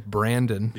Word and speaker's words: Brandon: E-R-I-C. Middle name is Brandon. – Yeah. – B-R-A-N-D-O-N.